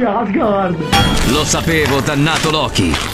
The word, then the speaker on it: Loki: No, non cambia, la pronuncia non cambia, è sempre Loki.